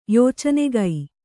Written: ಯಾಚನಕ

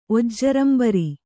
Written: ಒಜ್ಜರಂಬರಿ

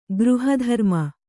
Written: ಗೃಹಧರ್ಮ

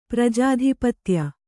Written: ಪ್ರಜಾಧಿಪತ್ಯ

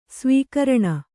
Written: ಸ್ವೀಕರಣ

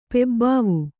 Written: ಪೆಬ್ಬಾವು

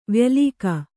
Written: ವ್ಯಾಲಿಕ

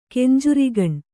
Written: ಕೆಂಜರಿಗಣ್ಣ

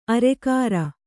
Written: ಅರಗುರಿ

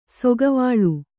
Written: ಸೌಗವಲ್ಲಿ

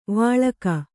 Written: ವಳಕು

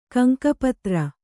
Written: ಗಂಗಾಪುತ್ರ

